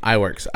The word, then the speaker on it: Iwerks: UB Iwerks is spelled UBIWERKS. First name, UB, UB. Last name, Iwerks, Iwerks.